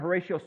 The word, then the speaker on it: Horatio: Uh, partly two reasons why, because when I first shared it, I mispronounced the author's name. I said Heratito. And it was in youth group in Toledo, Ohio, as I was serving as a youth director there, and one of the students said, or Horatio. I said, if you want to pronounce it correctly. Um, that song was written by a gentleman by the name of Horatio Spafford.